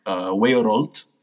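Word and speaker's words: weorold: This again goes back to Old English, in which world is something, would have been pronounced something like weorolt.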